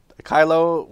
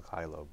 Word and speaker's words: Caylo: Kylo rhymes with silo. Mel Kylo.